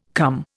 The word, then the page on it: cum: Indian